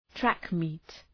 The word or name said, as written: track meet